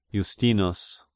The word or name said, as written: Justinus